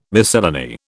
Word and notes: miscellany: British English

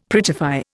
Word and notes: prettify: Indian

